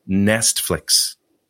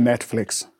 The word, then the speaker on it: Nestflix: Well, I think you're saying it wrong. I think it's pronounced Netflix. – Yes, you are thinking of the hugely popular streaming service Netflix, but I'm actually saying Nestflix with an S.